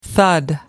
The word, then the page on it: thud: American English